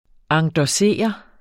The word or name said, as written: endossere